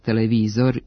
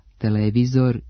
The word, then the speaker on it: televizor: Imenice televizor i radijator, koji imaju dugosilazne akcente na trećem slogu, pravilno se akcentuju kao oblici sa kratkouzlaznim akcentima na drugom slogu i dužinom na mestu starog akcenta, televizor i radijator.